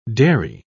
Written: dairy

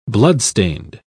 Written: bloodstained

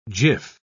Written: jiff

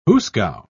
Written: hoosegow

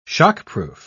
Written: shockproof

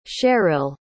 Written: Sherrill

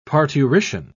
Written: parturition

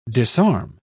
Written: disarm

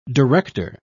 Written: director

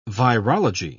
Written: virology

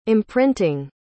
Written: imprinting

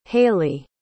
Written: hailie

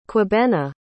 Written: kwabena

